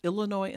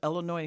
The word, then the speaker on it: Illinois: Would you tell Darren it's pronounced Illinois instead of Illinois? And he spelled it with an E.